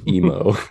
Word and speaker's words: emo: Emu, I would have. That would have been Emu versus Night Monkey. – It's possible my pronunciation was off. I meant emo.